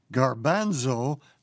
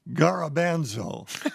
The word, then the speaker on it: garbanzo: She writes, "Dear Dr. Zorba, the word for chickpea is pronounced garbanzo, not garabanzo."